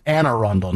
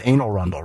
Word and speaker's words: Anne Arundel: And it's very important how you pronounce that. It's Anne Arundel, not Anne Arundel, right? – Exactly.